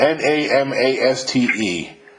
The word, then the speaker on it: namaste: It's spelled N A M A S T E. Now, yes. – So namaste. Namaste. namaste.